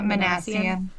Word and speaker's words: Minassian: It's Alec Manassian. Is that how you pronounce his name? – Yeah, Manassian.